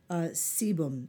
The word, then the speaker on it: sebum: You know, there's something called sebum. It's spelled S as in Sam, E, B as in boy, U, M as in Mary, which plays a major role in acne development.